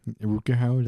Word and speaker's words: Rutger Hauer: Rutger Hauer, that's how you pronounce it.